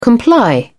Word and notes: comply: British English

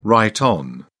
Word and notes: right on: British English

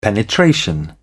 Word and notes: penetration: British English

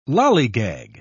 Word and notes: lollygag: British English

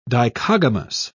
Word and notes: dichogamous: British English

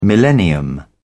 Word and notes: millennium: British English

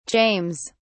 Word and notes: james: British English

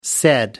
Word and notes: said: American English

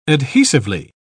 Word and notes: adhesively: British English

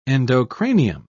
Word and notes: endocranium: British English

